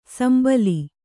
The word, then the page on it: ಶಂಬಲ: Kannada